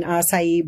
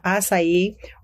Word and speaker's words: acai: So here you have an acai bowl. So you know the acai, or some people pronounce it acai, is a berry, one of the richest in antioxidants.